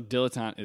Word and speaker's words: dilettante: Also, dilettante is three words. Tante. – It's three words? – Three words. It's dill, like the pickle.